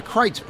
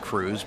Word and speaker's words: Kreuz: Turns out these two brothers were the Kreutz brothers, spelled K-R-E-U-Z. Yeah, that may look like it's pronounced Cruz, but it's Kreutz. It's German. The two Kreutz brothers got in a beef with each other years ago, typical family squabble, and they split the business.